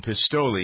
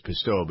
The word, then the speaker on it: Pistole: Here she speaks with the man who is the centerpiece of all the controversy, John Pistole. He says Pistole, but the real Italian pronunciation is Pistole, about the TSA controversy.